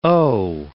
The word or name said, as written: o'